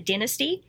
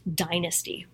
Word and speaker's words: Dynasty: It's totally off topic, but when I was at a party with a bunch of former child stars and I said Dynasty, and they all laughed at me because apparently it's pronounced Dynasty.